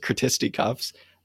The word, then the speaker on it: Critisticuffs: I think that's how I was told to pronounce it, Critisticuffs, who were giving away some of their pamphlets.